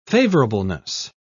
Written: favorableness

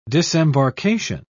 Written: disembarkation

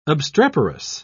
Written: obstreperous

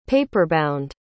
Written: paperbound

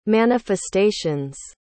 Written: manifestations

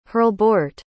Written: herlebeorht

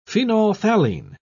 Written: phenolphthalein

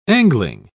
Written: angling

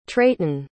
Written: treyten

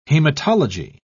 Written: hematology

